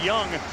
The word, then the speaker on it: Jung: Last name is spelled J-U-N-G, but it's pronounced Young.